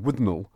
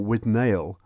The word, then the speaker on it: Withnail: Again, following a film that he made, which is one of the standards called Withnail, or Withnail, to pronounce it incorrectly, Withnail and I, with the same actor, Richard E.